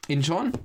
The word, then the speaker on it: Inchon: Yeah, a movie called Incon, I think I'm pronouncing it properly, was... – Inchon. – Inchon? Yep, so it's phonetic.